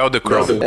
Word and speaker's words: Edelkrone: Acho que é Edelkrone, é eu não sei pronunciar o nome dessa. – é Edelkrone. É, essa aí mesmo.